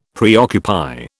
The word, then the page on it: preoccupy: British English